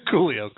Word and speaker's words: Coolio: When we were there, apparently, I think Julio. No, Julio. – Am I saying that right? – Maybe he meant Julio. – Coolio.